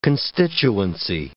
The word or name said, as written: constituency